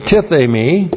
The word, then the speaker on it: tithemi: The verb here is tithemi. Looks like that in the Greek, T-I-T-H, long E-M-I, tithemi.